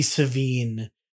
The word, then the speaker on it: Sevigny: That's Chloe Sevigny. I guess her name... – It would absolutely be Sevigny. – Sevigny.